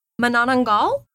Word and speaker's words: Manananggal: Oh, yeah. Manananggal. It's, that's the pronunciation that I looked it up and found someone pronouncing it in Tagalog. That's what I've been told is the pronunciation. If I'm incorrect, I'm so sorry. That's what I found. It's Manananggal.